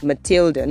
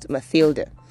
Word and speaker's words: Matilda: Now, Matilda could be spelt with TH or it could be spelt with just letter T. But either way, it is Matilda, not Mathilda.